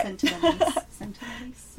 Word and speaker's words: Sentinelese: I hope I'm saying it right. Here, just look. This is how it's spelled. – Okay, let me see. I would say it the same way. – Okay. – Sentinelese. – Yes.